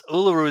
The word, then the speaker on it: Uluru: Hey, speaking of Australians, you just got back from Uluru. Is that how you pronounce it? – Yes. Uluru is the biggest monolith in the world.